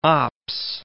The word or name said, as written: Ops